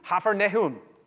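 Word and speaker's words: Kfar Nahum: We get to the city, the guide is there with us, and he says, "Welcome to Hafernehum." And I said, "What?" That's how it's Hafernehum. That's how you pronounce it.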